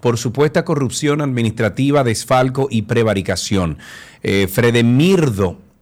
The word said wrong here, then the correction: Fredermido: no, perdón, lo dije mal el nombre, Fredermido